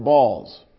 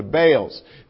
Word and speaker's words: Baals: It also produced some new merchandise because the winner was Harry B-A-A-L-S. He pronounced it balls. Really. The family has since changed the pronunciation to Bales.